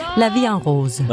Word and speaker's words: La Vie en Rose: Edith Piaf, La Vie en Rose. – Ja. – Som jeg overhovedet ikke udtaler rigtigt. – Nej. – Hvordan skal det siges? – La Vie en Rose.